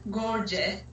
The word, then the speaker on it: Görce: My last name is Görce, which is Gr. – again the second letter is O with the two dots just top of the O. Actually, this is like A and E voice, like Görce.